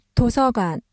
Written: 도서관

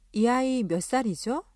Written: 아이

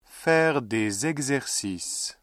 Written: faire des exercices

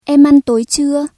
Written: bữa tối

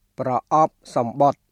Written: ប្រអប់សំបុត្រ

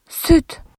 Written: süt

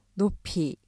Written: 높이

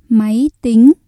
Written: máy tính

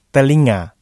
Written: telinga

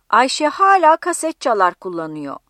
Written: kasetçalar

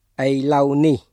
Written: ឥឡូវនេះ

